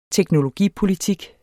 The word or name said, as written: teknologipolitik